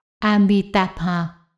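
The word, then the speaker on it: Amitābha: Cách đọc âm chính xác của A-di-đà trong tiếng Phạn là Amitabha.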